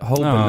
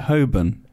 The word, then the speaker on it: Holborn: Holborn. – I think. – I say Holborn. – The L is silent, I believe.